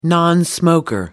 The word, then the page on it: nonsmoker: British English